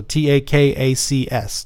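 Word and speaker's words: Takács: Um, it in the book it's spelled T A K A C, and there's supposed to be an S on the end of that, so T A K A C S, Takacs.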